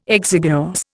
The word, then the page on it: exiguous: Indian